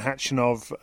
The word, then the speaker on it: Khachanov: Catherine, first of all, we're going to hear, as I said, from Andy Murray talking to you after his victory over Karen Khachanov a little bit later in this show.